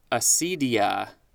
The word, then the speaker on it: acedia: Moving on, next word is acedia, A-C-E-D-I-A.